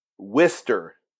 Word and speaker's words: Worcester: I was traveling through the States with my job, and I think we went there, and I looked it up online, and it said that was pronounced Worcester. Like, they asked a bunch of people that were from there, said, how do you pronounce? And they had the, you know, W-O-R-C-E-S-T-E-R. And they said, Worcester. Like, W-I-S-T-E-R is almost how it sounded.